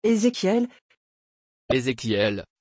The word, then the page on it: ezechiel: French